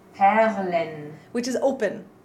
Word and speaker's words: Perlen: And I think it's interesting that you actually pronounce the R there with the tongue. You actually say a perlen. Well, you say it rolled in the back, and I can't do that, so.